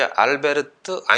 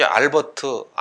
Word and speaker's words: 알베르트: 아무튼 간에 독일 발음으로 이름을 발음을 하면 이제 알베르트 아인슈타인이 되는 것, 이게 아마 보다 더 정확한 발음인 것 같습니다.